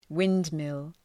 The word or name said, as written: windmill